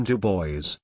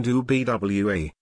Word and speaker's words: Du Bois: Dr. Du Bois pronounced his name Du Bois, rather than Du Bwa, in the French manner.